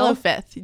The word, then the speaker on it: Hello Fifth: This was a trick that I felt like I got from Hello Fifth. Did I say that right? Yeah. – Yeah. Jill. – Hello Fifth.